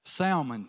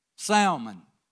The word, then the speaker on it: salmon: And I put the L in there, salmon, the way it's spelled. And my son would say, Dad, it's not salmon, it's salmon. I said, how do you get salmon out of S-A-L-M-O-N?